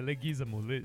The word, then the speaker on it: Leguizamo: O que ele fala, o Bob Hoskins, é o seguinte, eu não consigo pronunciar o nome daquele cara que faz o Luigi, é Leguizamo, Leguizamo.